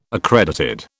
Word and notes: accredited: British English

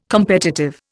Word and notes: competitive: Indian

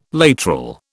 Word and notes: lateral: British English

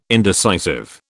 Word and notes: indecisive: British English

